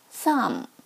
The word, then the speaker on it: thumb: He put in his thumb, thumb 大拇指，这里需要注意一下，字母 b 是不发音的。 thumb, plum, plum 李子，thumb, plum。